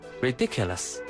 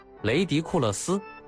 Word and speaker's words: ridiculous: 让我想想，有一个 ridiculous。 – 为什么记得这么牢？ – 高一有一次英语课，我回答问题读错了这个词的发音，读成了雷迪库勒斯，全班笑疯了，老师也没忍住。